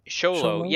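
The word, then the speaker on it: Xolo: Is that how you say his name, Sholo? – Sholo, yeah, I read an interview piece with him, and they said it's pronounced Sholo.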